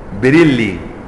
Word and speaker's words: بريلي: هي البلد اسمها بريلي بريلي، فالنسبة اليها بريلوي، مفتوح للبريولي للبريلوي للبريولوي للبريلوي القبوري الغالي.